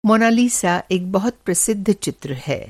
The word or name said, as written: मॉना लिसा